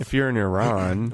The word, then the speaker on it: Iran: or sorry, Iran. I prefer the phrase, the pronunciation Iran.